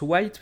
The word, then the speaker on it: White: Euh, Sylvain White, en fait, je pense qu'on prononce White puisque son père était un basketteur américain.